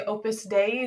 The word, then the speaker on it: Opus Dei: We say Opus Dei. Is that how you say it? – Yes.